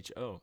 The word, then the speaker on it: Sappho: You can go type in Sappho or Sappho, however you want to pronounce it, Ode to Aphrodite. It's Sappho.